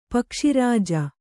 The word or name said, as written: ಪಕ್ಷಿರಾಜ